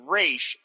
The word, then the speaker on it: Ra's: Anyway, pronunciation is Ra's, and I'll tell you why. The name of Frank's autobiography is I'll tell you why. A lot has been made of using the Arabic pronunciation of Ra's, but Denny O'Neill has stated that the intent was for the character to be of indeterminate ethnicity, hence the Hebrew twang on the Arabic name.